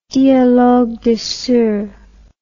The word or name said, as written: dialogue des sourds